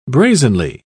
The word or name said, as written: brazenly